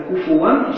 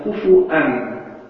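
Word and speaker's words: куфуан (كُفُوًا): Куфу читается куфуан, читается куфуан. И оба одно и то же значение, значит наравне.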